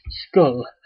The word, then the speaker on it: Schull: to death beyond Skull, pronounced or spelt Schull,